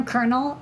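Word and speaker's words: colonel: Can we talk about how the word kernel isn't spelled anyway the way it's pronounced? Like, yeah. – The military term, kernel.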